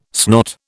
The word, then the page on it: snot: British English